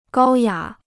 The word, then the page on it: 高雅: gāo yǎ